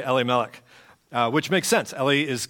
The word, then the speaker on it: Elimelech: And Elimelech, which I talked to my rabbi friend this week, and we have always pronounced it Elimelech, and I pronounced it that way, and she said Elimelech, and I was like, oh.